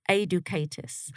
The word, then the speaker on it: Adicatus: So my last name is pronounced Adicatus. – Okay. – So it's close. It's kind of like Educatus as a kind of a breakdown.